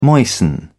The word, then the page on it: moisten: British English